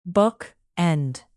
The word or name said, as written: bookend